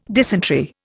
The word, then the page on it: dysentery: Indian